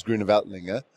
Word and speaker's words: Grüner Veltliner: Obviously, the big grape variety from Austria is Grüner Veltliner.